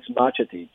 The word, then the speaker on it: Marčetić: Yeah, the last name is Marčetić. – Marčetić, okay. – Yeah, so it's a Serbian name, so originally those C's would have little symbols that denote that you pronounce it with a ch sound.